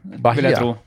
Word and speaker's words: Bahia: Uh, Fluminense mot uh, Bahia, eller hvordan man uttaler det. Bahia? Bahia? – Ja, nå, nå, skulle, Bahia.